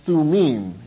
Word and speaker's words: Thummim: Urim in Hebrew and Thummim is literally how it's pronounced.